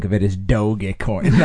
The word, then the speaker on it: Dogecoin: Or you might have said doggy coin at first. It is Dogecoin, though.